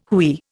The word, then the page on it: hooey: Indian